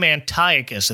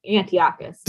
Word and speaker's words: Antiochus: And of course, Anton is standing in for, well, they pronounce his name Antiochus in this special. – Yeah, I've always heard Antiochus.